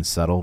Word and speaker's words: subtle: Do you think it's weird that in subtle, we don't pronounce the B? – I think so, yeah.